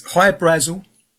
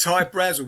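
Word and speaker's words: Hy-Brasil: There's High Brazil, people pronounce it High Brazil, and it's Brazil, okay. – Yeah. But it's High Brazil because that's how the Irish would pronounce it, because it's it's an island that's off the southwest coast of Ireland.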